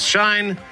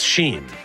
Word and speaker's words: Shein: I thought it was Shine. It's Sheen.